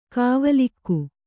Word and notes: ಗಾವಿಲಿಕೆ: gāvilike

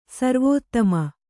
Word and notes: ಸರ್ವೋತ್ತಮ: sarvōttama

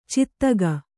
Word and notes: ಚಾತಕಿ: Kannada

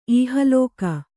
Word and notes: ಇಹಲೋಕ: ihalōka

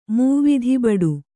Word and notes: ಮೂವಿಧಿಬಡು: kannada

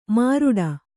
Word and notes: ಮಿರಡಿ: Kannada

